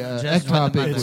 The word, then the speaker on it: ectopic: So in your sermon on the application in regards to abortion, you did not address ectopic, am I saying it correctly? – Yeah.